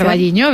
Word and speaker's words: O Carballiño: O Carballiño, no lo sé pronunciar. – O Carballiño, bien, bien.